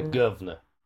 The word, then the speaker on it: guvnor: The governor. It's spelled G-U-V-N-O-R.